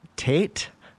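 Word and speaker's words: Taet: And I'm actually going to play a track from Error Grid artist Tate, I believe is how you say it. It's T-A-E-T.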